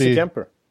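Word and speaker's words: Darcy Kuemper: Coyotes get goalie Dracy eh, Camper. Hur uttalar man det då? – Darcy, Darcy Camper.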